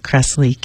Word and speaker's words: Kressley: Megan, M-E-G-G-A-N, Kressley, K-R-E-S-S-L-E-Y.